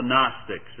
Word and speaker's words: Gnostics: Now, the big problem was some folks who we call the Gnostics. It's spelled capital G-N-O-S-T-I-C-S. The G is silent, as is the P in our English word pneumonia.